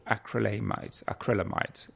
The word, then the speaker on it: acrylamides: The other big group of carcinogens are called acrylamides, which is how you say it.